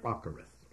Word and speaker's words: acharith: Now, here we have the first occurrence in the Old Testament of the Hebrew word acharith. That's spelled A-C-H-A-R-I-T-H, acharith.